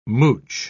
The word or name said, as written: mooch